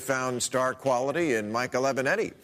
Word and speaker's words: Avenatti: and it's great the way he makes Trump so mad he can't pronounce his name right. – Another woman just reported by a sleazebag lawyer named Aviate.